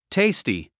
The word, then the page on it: tasty: téisti; テイスティ